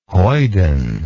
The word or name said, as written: hoiden